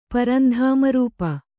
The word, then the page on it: ಪರಂಧಾಮರೂಪ: kannada